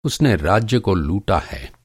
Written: लूटना

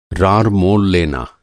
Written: रार

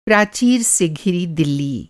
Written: प्राचीर

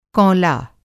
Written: कौंला

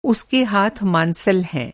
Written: मांसल

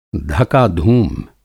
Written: धका-धूम

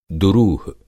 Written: दुरूह

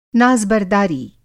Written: नाज़-बरदारी

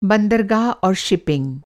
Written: शिपिंग

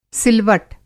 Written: सिलवट

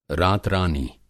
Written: रात-रानी

